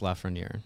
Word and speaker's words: Lafreniere: Alexis Lafreniere.